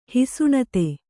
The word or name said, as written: ಹಿಸುಣತೆ